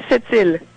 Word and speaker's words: Sept-Îles: You pronounce it Sept-Îles.